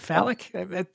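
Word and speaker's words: Falic: It's F-A-L-I-C. Yeah. Phallic. That's how I'm pronouncing it.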